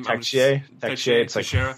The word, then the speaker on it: Teixeira: So Brett and Shane both ask, not Brett, but Shane asked what we thought of is it Teixeira on Columbus, is that how you say his name? – Teixeira. – Like, I'm thinking of like a baseball player, bench. So I said Teixeira.